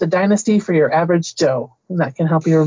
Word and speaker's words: Zhou: The second dynasty in this list is the Zhou. The Zhou dynasty is spelled Z-H-O-U, and Z-H in that situation is just kind of like an English J.